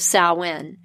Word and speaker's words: Samhain: And the oldest is Samhain, and that is spelled S-A-M-H-A-I-N. There are various pronunciations, but this is the most common pronunciation.